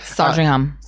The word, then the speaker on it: Sandringham: Sandringham.